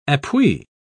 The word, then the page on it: appui: British English